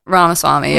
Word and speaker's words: Ramaswamy: I'm gonna butcher this name again. And we looked this up earlier because I knew I would do this. Vivek Ramaswamy. – Ramaswamy, yeah. – Ramaswamy. Okay. I knew I was going to do that.